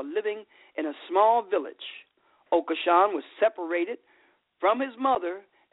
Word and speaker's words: Okosun: His name is Ambrose, and I think it's pronounced Okoshun, O-K-O-S-U-N,